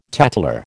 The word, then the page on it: tattler: American English